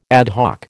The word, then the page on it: ad hoc: American English